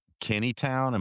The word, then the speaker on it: Kennytown: And I believe the Kennytown, am I pronouncing that right? I have a Southern, so I pronounce everything wrong. – You got it right.